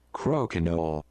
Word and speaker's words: Crokinole: So you do pronounce the L. – Crokinole. – So they say. – Yeah. – I looked at two of them and they pronounced the L. – Yeah. – And then multiple people saying you don't pronounce the L. – I'm pronouncing the L. – Yeah? – It's in there for a reason.